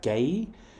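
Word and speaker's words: Guéhi: I think it's Gay, G-U-E-H-I.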